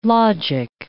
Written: logic

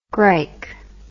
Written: gryke

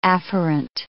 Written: afferent

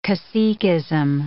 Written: caciquism

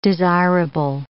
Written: desirable

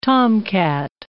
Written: tomcat

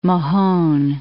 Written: Mahon

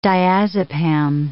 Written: diazepam

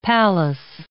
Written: palace

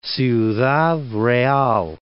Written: Ciudad Real

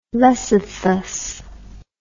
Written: lecythus

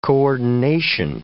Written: coordination

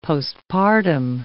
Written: postpartum

